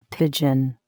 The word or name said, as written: pigeon